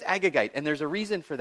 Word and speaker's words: Agagite: Okay, you have to say it like that. Agagite. Say it with me.